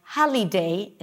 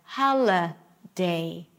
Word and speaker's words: holiday: Or the I will be pronounced as E, like holiday instead of hala day.